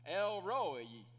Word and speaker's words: El Roi: If you're curious about pronunciation, if you're from Georgia, you'd have no problem pronouncing this. Because you'd look at it and you'd say, Roi. Right? El Roi. Hannah also says it like that from Arkansas. But that's how you pronounce it.